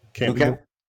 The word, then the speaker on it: Camp Nou: Camp Nou.